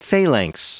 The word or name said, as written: phalanx